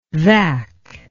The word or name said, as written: vac